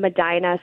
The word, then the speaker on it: Medina: You also service Medina or Medina? I forget how it's pronounced. – Yep. So we're right in Medina.